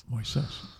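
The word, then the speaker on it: Moisés: It's emphasis on the I, Moises. – Moises.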